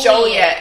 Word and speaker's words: Joliet: Yes, it's Joliet. If you are a local and you still call it Joliet, is a big thing. People say Joliet. – How do you spell it? – J-O-L-I-E-T. – It's one L. – Yeah. – So it's Joliet, not Joliet.